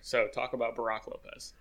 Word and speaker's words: Brook Lopez: Brook Lopez. – I said it like that on purpose. – Did you though? – Brook Lopez.